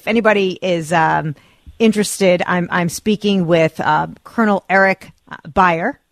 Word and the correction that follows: Buer: Did I say it right, Colonel? – Buer. – I don't know why I can't get it right, David. I'm so sorry. David's going to have to write everything out phonetically for me. Colonel Eric Buer,